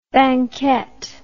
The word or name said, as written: banket